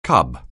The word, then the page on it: cub: anglais